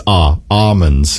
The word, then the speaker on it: almonds: And I said, she's like, you pronounce the L, Orina. – That's a good point. Yeah, no, she's wrong though. You do not. It's almonds. Yeah, there's no L said.